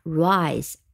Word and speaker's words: rise: 读成和字母 a 一样的读音 a raise，那么 rise 呢？因为后面有一个 e，所以呢，它也是一个非常规则的读法 rise r i s e。